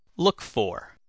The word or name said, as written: look for